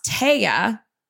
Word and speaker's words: Taya: It's actually pronounced Taya, but in Spanish, T-A-Y-A says Taya.